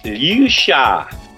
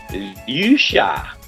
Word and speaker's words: Liu Xia: As I was told, it's Liu Xia. Liu Xia. So okay. – It's spelled L-I-U-X-I-A.